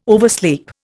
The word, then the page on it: oversleep: Indian